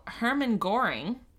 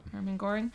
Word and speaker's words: Hermann Göring: A morning mosquito attack knocked out the main Berlin broadcasting station while Hermann Göring, I'm pronouncing that right. – Sure. – Hermann Göring, was speaking, taking his speech off the air.